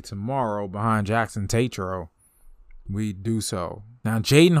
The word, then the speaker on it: Tetreault: Jackson Tatro. And you look at his name, it's TETREAULT. When I looked at his name, I was like, that sounds like Tatro? That's how you say his name?